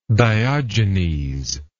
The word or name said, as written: diogenean